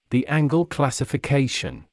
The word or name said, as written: the Angle classification